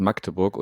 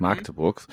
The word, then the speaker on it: Magdeburg: Ich muss mir jetzt gerade selber, habe ich jetzt festgestellt, ich habe es eben wieder falsch gesagt, das heißt Magdeburg und nicht Magdeburgs. Weiß nicht, warum man das, wenn man nicht in der Region beheimatet ist, dann das A immer gerne ein bisschen langgezogen. Also Magdeburg.